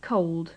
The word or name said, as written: cold